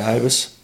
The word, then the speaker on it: Ibis: Is that how you say it? Ibis? – Yeah, Ibis.